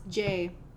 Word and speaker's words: 7AE: Pronounced J. So it's J, but it's spelled 7-A-E.